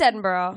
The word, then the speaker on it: Edinburgh: it's Edinburgh.